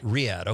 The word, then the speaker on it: Riyadh: Is it Riyadh? – Riyadh, okay.